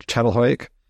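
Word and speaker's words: Çatalhöyük: And also when you're talking about, I don't know how to say it right, chattelhoik. – Yeah, that's right.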